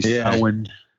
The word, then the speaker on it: Samhain: He pronounces it wrong. It's actually Samhain.